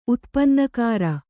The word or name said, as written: ಉತ್ಪನ್ನಕಾರ